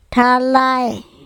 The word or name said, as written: THalae